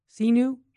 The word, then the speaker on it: sinu: It's S-I-N-U and then Jesus. How would you pronounce that in Latin? Sinu? – Do that again. – S-I-N-U in Latin.